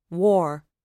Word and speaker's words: were: Another correction he made was to change was to were, which in dialect is pronounced war.